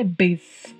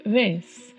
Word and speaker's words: vez: B de bola, mas é um B assim mais suave, vez Não é bes né? É vez Você faz o, a, o formato do lábio como se fosse falar o B, mas suaviza um pouco na pronúncia, vez.